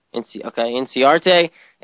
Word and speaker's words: Inciarte: Ender Inciarte. Is that how you say it? Inciarte? – I think it's just Inciarte, yeah. – Inciarte and Caleb Joseph for GS over there.